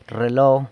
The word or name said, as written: rrelo